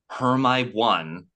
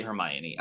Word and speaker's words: Hermione: I had to die a little bit because Hermy One was maybe my favorite line in the show. Because as a young American Montana boy, I had no idea how to say Hermione.